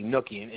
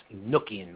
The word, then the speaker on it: Enochian: What Enochian Magic, or Enochian, it should be actually Enochian, and it has nothing to do with the prophet Enoch. It's actually pronounced Enochian Magic.